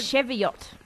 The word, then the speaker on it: Cheviot: How do you say that? – Cheviot. – Cheviot Beach.